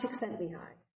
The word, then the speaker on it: Csikszentmihalyi: Mihai Csikszentmihalyi is how you pronounce his last name.